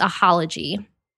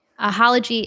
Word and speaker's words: Ahalogy: But the one that I personally love is Ahology. – Okay, and with spell that for me. – Ahology is A-H-A-L-O-G-Y.